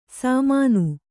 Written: ಶಾಮನ

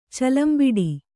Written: ಚಲಂಬಿಡಿ

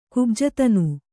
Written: ಕುಬ್ಜತನು

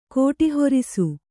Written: ಕೋಟಿಹೊರಿಸು

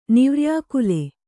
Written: ನಿವ್ರ್ಯಾಕುಲ

